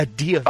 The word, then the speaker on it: Deovel: A Deovel.